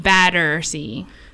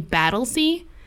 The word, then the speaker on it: Battersea: So the story I have today, it's called the Battersea Poltergeist. – Like, have you Battersea? Heard? – Battersea. – Like D-D-E-R? – T-T-E-R. – Oh, like beer batter. – Like beer batter, yeah.